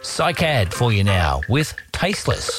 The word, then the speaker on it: Cycad: We're on to a Melbourne four-piece band now called Cycad. I think that's the way it's pronounced, C-Y-C-A-D.